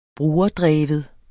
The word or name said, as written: brugerdreven